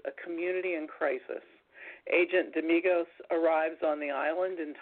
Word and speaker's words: Damigos: It's a playful romantic thriller set in contemporary Greece featuring Nick Damigos. Tell me if I'm saying that wrong, Tim. A gay Greek-American. – You're right.